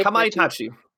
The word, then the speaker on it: Kamaitachi: I will never say this correctly. Kamaitachi. – Kamaitachi.